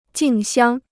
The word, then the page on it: 竞相: jìng xiāng